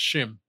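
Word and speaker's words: Schim: I'm trying to remember if I'm saying that right. Oh, um, where is it? I just saw it. Shim. Schwim. It's S S C H I M. So that's Shim.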